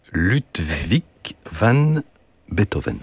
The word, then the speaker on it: Ludwig van Beethoven: Un autre compositeur, apparemment, que je ne connais pas, Ludwig van Beethoven.